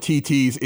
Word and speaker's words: Thithi: So many things to discover over at TT's. It's spelled T-H-I-T-H-I.